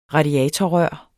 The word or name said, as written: radiatorrør